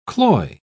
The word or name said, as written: cloy